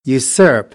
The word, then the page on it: usurp: American English